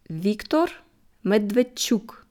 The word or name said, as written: Viktor Medwedtschuk